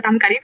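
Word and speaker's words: Tamkharit: Bueno, pues sí, efectivamente viví la Tamkarit, que se dice, las pronuncia muy bien, es el Año Nuevo Musulmán, y que también la llaman la fiesta del cuscús.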